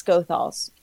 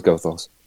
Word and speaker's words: Goethals: I love the actress in it who another one I grew up with, I want to say, not sure how to pronounce her last, but I think it's Goethals. But Angela Goethals, we're just going to go with that until someone tells me differently.